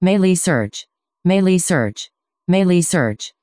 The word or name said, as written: meilisearch